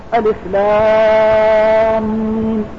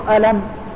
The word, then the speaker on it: ألم: فأنت إذا نظرت إلى قوله سبحانه وتعالى ألف لام ميم فإن هذه الثلاثة الحروف إذا نطقتها نطقا ظاهرا تقول ألم، ولكنها بالرواية والسماع والتلقي تنطق على هذا الوجه مفصلة مبينة كل حرف على حدة، لأنها أخذت بالرواية والسماع، بل إن الرواية والسماع قد تسقط بعض الحروف في كتاب الله.